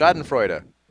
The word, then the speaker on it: Schadenfreude: Is that how you pronounce it? – Schadenfreude.